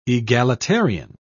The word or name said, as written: egalitarian